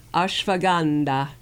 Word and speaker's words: ashwagandha: And here's their report in Europharmacopoeia about ashwagandha. Did I say that right, Gary? Ashwagandha. He's giving me thumbs up on that one.